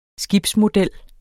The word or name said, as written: skibsmodel